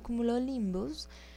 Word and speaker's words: cumulonimbus: Que se llaman cumulonimbus.